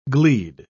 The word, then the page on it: gleed: British English